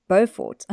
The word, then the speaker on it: Beaufort: Now, Braeside is a farm just out of Fort Beaufort. Beaufort. Wait. – Beaufort. – Beaufort. I thought I was saying that wrong.